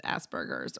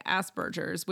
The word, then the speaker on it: Asperger's: Well, it turns out then that Connor gets diagnosed with Asperger's, or as they say, Asperger's, which makes me wonder, is this a GIF situation? – They say it with a soft G.